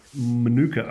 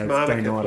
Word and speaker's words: Manuka: That game is on Thursday at Manuka Oval, as I prefer to say. – That's pronounced Manuka, please.